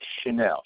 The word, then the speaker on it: Channell: David Chanel. Chanel is spelled C-H-A-N-N-E-L-L.